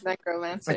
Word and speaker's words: necromancer: Yeah, it's necromancer.